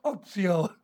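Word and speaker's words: oopsio (ὀπίσω): It's spelled like this, O-P-I-S-O. It almost sounds like oops, oopsio, oops.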